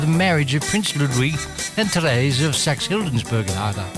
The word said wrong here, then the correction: Saxe-Hildburghausen: I've pronounced that completely wrong. How about that? Yes, Prince Ludwig and Therese of Saxe-Hildburghausen. Thank you, John.